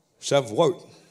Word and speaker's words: Shavuot: And, you know, Shavuot, is that how you say it? Shavuot. Okay.